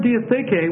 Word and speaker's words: diatheke: And that's spelled D-I-A-T-H, long E, K, long E. And this word diatheke, what it means is a testament, or a covenant, or a will.